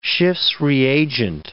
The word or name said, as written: Schiff's reagent